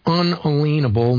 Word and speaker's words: unalienable: The word should be with the UN. Unalienable, in the way I pronounce it, just to make a distinction between inalienable and unalienable.